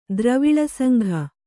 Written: ದ್ರವಿಳಸಂಘ

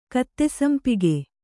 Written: ಕತ್ತೆಸಂಪಿಗೆ

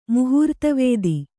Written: ಮುಹೂರ್ತವೇದಿ

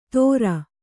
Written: ಥೋರ

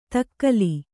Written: ದಕ್ಕಲು

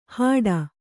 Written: ಹಾಡ

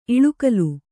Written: ಇಲಿಗುಲಿ

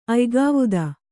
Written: ಅಯ್ಗಾವುದ

